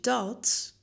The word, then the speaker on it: da: Het woordje dat spreken ze uit als da.